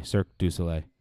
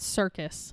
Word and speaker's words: Cirque du Soleil: Cirque du Soleil. That's, I have no idea. That's how you say it? – Yes. – Bullshit. – It's not circus. – Ah, fuck. I know. Was I saying circus? – Yeah, all right. You've always said circus.